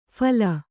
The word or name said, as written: ಫೈಲು